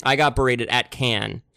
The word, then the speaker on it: Cannes: A little film festival I like to call Cannes. And it's not Cannes. – Oh. – It's not Cannes. There's a lot of different ways of pronouncing it, but it is Cannes.